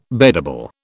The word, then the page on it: beddable: British English